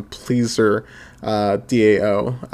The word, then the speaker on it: PleasrDAO: And then Kate gives this really nice rundown on Pleaser DAO. It's spelled PLEASRDAO.